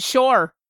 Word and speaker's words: Pschorr: P-S-C-H-O... Wait, P-S-C-H... – P-S-C-H-O-R-R. – P-S-C-H-O-R-R. How would you pronounce that in German? – Ach, Freund Leben! – It's Shore. You would pronounce it? Shore.